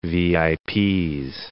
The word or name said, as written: VIPs